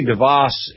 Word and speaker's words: DeVos: Betsy DeVos is, I think, the preferred pronunciation.